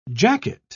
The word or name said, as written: jacket